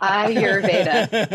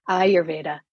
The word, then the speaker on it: Ayurveda: You are. Yeah. Right. Ayurveda. Yeah. All right. Yeah. Ayurveda. So like an eyeball and then the pronoun your. Ayurveda. Yeah. – Okay. – Ayurveda. So Ayurveda is a Sanskrit word, and it literally means the science of life or the knowledge of life or the wisdom of life.